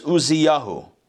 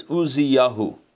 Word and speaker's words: Uzziyahu: In the year of the death of King Uzziah, in Hebrew it's Uzziyahu. Maybe in English it's Uzziah. I'm not sure how you pronounce it in English. But in Hebrew it's Uzziyahu.